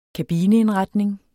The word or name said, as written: kabineindretning